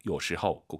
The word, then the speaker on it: 有时候: 有时候 아니죠. 오유는 어우 발음이에요. 허우예요. 有时候. 꼭 기억하세요.